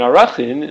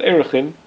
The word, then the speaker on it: Arachin: So the Gemara in Arachin, in Chav Chesam Al, from the Sechs Arachin, says, often pronounced Erechin, but that's incorrect, says that if they use the same expression, Miket, Shevashanim, in both places, so it must be some at the same time.